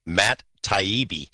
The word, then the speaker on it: Taibbi: The guy who wrote that article in Rolling Stone, his name is pronounced Matt Taibbi.